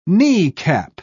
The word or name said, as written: kneecap